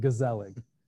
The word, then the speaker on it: gezellig: But we really established, we found through a lot of digging, we uncovered this word in Dutch culture, which is, we're going to pronounce it gezellig. It's G-E-Z-E-L-L-I-G. You could pronounce it Dutch if you want. It's a little more H-y, a little more hard to say.